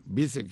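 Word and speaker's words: Vizer: Bizet, creo que así no se escribe, pero creo que se pronuncia así, Bizet. B-I-Z-E-R.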